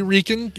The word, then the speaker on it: Reichen: I would say Reichen. If it's German, you always pronounce the second of the two vowels.